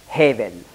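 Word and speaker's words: Hevel (הבל): Auf Hebräisch spricht man das aus, Hevel.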